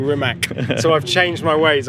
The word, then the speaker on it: Rimac: Okay, so welcome to the Top Gear magazine podcast, Mr. Bugatti Rimac. I'm finally pronouncing your surname correctly. – Yes. – I think. – I don't care how you pronounce it, it's fine either way, but you've done it very well.